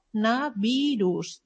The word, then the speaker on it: coronavirus: Per tant, el que hem de fer és coro, coronavirus, d'acord? La segona O la pronunciem com una O. No hem de caure amb la ultracorrecció, diria, no? D'intentar fer-ho tot reduït i fer coronavirus. D'acord?